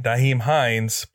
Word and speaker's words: Nyheim Hines: But I'm going to try the name, Nyheim Hines. Please don't not stop listening to the show because I didn't say his name right. – I think you said it right.